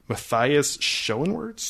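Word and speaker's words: Matthias Schoenaerts: This cast consisted of Carey Mulligan, Matthias Schoenwerts, that's how you say his name.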